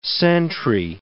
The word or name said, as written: sentry